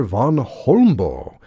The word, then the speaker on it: Vagn Holmboe: Music by Danish composer Van Holmboe. That's spelled V-A-G-N-H-O-L-M-B-O-E.